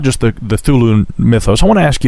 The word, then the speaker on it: Cthulhu: Well, I pronounce it Cthulhu.